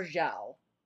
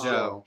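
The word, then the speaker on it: Zhou: Is it Joe or Zhao? We were mispronouncing Joe. it for so long. Joe. Joe. – Joe at thirteen.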